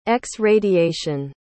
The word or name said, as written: x-radiation